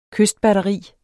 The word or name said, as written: kystbatteri